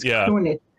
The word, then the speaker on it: Krunic: Am I getting your last name right? – You absolutely got that right. Oh. Thank you for pronouncing it correctly. Most people don't, but that's fine. It's Krunic.